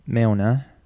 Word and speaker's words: Mjolnir: Mjolnir.